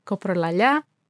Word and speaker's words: coprolalia: and it sounds like coprolalia in Greek, and both of which are really, really rare.